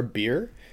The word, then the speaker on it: Bheer: Well, and later on, the humans are drinking something called B-H-E-E-R, beer, which doesn't taste like beer, but it's the H is silent, and there's different kinds of alcohol.